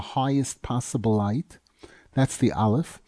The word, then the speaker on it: Aleph: The letter Aleph is always usually refers to keser, because the way we pronounce it, aleph, it's the same letters as the word pele.